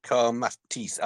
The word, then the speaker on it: kamatis: Kamata, kamata. It's spelled with a K, isn't it? Spelled with same. – Kamata. Close. Kamatis. – Mata. Kamatis. Oh, kamatis. Yeah. – Kamatis in Tagalog.